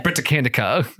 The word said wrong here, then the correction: Britannica: I'm like, no, it's Britannica.